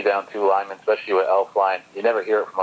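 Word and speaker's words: Elflein: By the way, for the Vikings, their center is out today, I can't pronounce his last name, Elf Line, is that correct? – Yeah, exactly. Yep, you got it.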